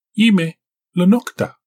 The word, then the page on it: yime lnok da: Galindri